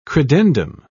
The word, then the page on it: credendum: British English